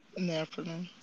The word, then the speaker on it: Nephilims: How is it pronounced? The Nephilims? Nephilims? – Nephilims.